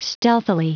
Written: stealthily

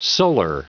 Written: solar

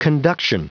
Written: conduction

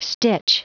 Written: stitch

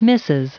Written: mrs

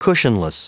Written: cushionless

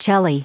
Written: celli

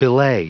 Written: belay

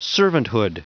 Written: servanthood

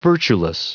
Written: virtueless

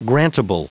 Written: grantable

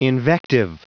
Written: invective

